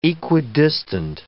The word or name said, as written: equidistant